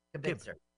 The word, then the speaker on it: Kibitzer: Kibitzer. – Like kibbles and bits. Kibitzer.